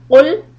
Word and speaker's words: قل: Now, when we start, the first word is قل. There's a difference between the pronunciation of the قاف with the two dots and the كاف. If you say قل, it means to eat. And قل means say.